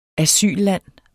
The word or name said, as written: asylland